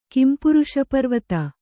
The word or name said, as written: ಕಿಂಪುರುಷಪರ್ವತ